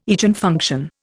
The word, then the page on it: Eigenfunction: Indian